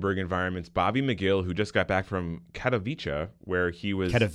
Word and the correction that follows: Katowice: Sorry, it rhymes with pizza. Katowice.